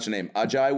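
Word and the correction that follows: A'ja: Wilson, right? – Oh, Asia. – Asia Wilson.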